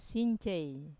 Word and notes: since: wamesa

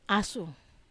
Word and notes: asu: Wamesa

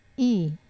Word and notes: i: wamesa

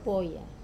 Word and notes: poya: wamesa

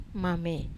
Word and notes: mamei: wamesa

